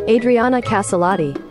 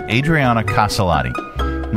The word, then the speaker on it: Adriana Caselotti: Adriana Caselotti. – All right, Adriana Caselotti.